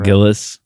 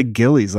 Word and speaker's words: Gillis: Gillis. – Gillis. I always want to say Gillies, like Gilligan. – Because it's spelled like Gillies.